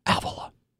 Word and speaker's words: Avila: Yeah, just so you all know, the correct pronunciation is Avila.